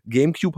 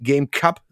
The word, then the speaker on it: GameCube: dass die Konsole von Nintendo GameCube heißt und nicht GameCup, so wie ich hm. es früher ausgesprochen hatte, weil meine Engl Englischkenntnisse wirklich eine absolute Katastrophe damals gewesen sind.